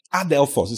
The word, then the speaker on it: adelphos: Adelphos is spelled A-D-E-L-P-H-O-S. Adelphos. Can you show it to us?